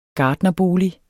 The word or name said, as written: gartnerbolig